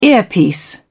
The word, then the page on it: earpiece: British English